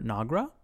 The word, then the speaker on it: Nagra: It aired one season, obviously, from January to May, starring Sarah Jones, Jorge Garcia, Sam Neill, and Parminder Nagra.